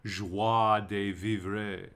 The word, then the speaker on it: joie de vivre: Joy comes from the French joie, J-O-I-E, as in joie de vivre. Joy de vivre. I can't pronounce that right. Joy de vivre. Joy de vivre. Or as the YouTube pronunciation guides teach me.